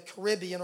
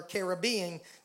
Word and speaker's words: Caribbean: And in 1977, they were appointed missionaries to the Windward Islands in the Caribbean, or Caribbean, depending on how you say it.